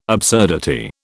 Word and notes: absurdity: British English

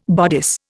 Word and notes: bodice: Indian

